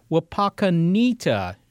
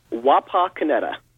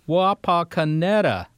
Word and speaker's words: Wapakoneta: And checking our program, I find that we pronounced it Wapakoneta. Is that wrong? – From what I understand, yes, that is incorrect. – Well, tell us what we should have said. – Wapakoneta. – Wapakoneta.